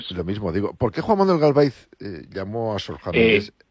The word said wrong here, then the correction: Galaviz: Sí, antes que nada, una corrección. Es Juan Manuel Galaviz Herrera. – Disculpa, digo Galaviz, la pronunciación.